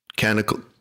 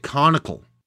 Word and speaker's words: canonical: Canonical. – Would you leave me alone with my pronunciation? What is it? Conical.